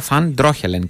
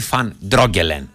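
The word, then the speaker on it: Van Drongelen: Εμένα μου είπανε, πότε μου είπανε και μου άρεσε, ότι με βάση την προφορά πρέπει να τον λέω Φαν Ντρόχελεν και όχι Φαν Ντρόγκελεν. Θα τον λέω Φαν Ντρόχελεν. Μου πάει και καλύτερα. Φαν Ντρόχελεν και όχι Φαν Ντρόκελεν.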